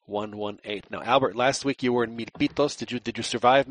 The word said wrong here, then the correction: Milpitas: It's actually Milpitas. So whatever. – Well, I mean, the you know in English they pronounce it you know mil, mil, Milpitas. So I mean that that to me sounds like Milpitos.